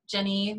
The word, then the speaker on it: Gennie: It's GENNIE, Jenny, my name that is spelled really in a bizarre way.